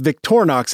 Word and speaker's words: Victorinox: So it's Victorinox is the brand name.